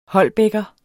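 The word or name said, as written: HOLBÆKKER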